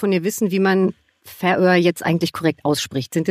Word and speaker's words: Färöer: Die korrekte Aussprache ist die Färöer, Betonung auf der ersten Silbe.